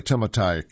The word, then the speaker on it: Timotei: By the way, Timotei can be pronounced in English Timothy, but I'm trying to be as close to the native pronunciation as I can.